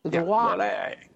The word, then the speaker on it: Loire: I think it's Loire. I don't think it's Loire. But anyways. – How do you know? – Proceed. – Can you see it? – Yeah. – How do you spell it? – L-O-I-R-E. – Loire. – Loire. You mangle the English language, let alone French.